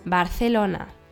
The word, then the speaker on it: Barcelona: Das C mit einem E oder I wird als Z ausgesprochen und nicht als S. Barcelona und nicht Barcelona.